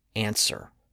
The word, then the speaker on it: answer: Think about the word answer. It's spelled A-N-S-W-E-R, but we don't pronounce the W. It's not answer, it's answer.